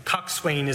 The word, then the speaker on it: coxswain: Coxswain is how it's pronounced, but it rhymes with Dotson, coxswain.